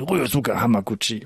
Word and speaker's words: Ryosuke Hamaguchi: Das Glücksrad, der deutsche Titel, der neueste Film von Ryosuke Hamaguchi.